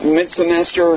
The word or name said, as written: midsemester